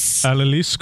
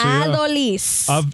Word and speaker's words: Adolis: Adolis Garcia. – Adolis.